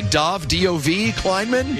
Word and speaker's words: Dov Kleinman: Dov, D-O-V, Kleinman? – Yes.